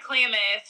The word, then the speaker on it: Klamath: Yeah, Klamath.